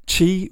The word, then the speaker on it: qi: and in which qi is spelt qi, pronounced with a fourth tone in Mandarin, a falling tone that sounds a little bit like you're saying it whilst putting your foot down.